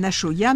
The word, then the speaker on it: axoa: Alors, Marie, moi, je veux bien que ce soit un achoya, mais ça s'écrit A-X-O-A.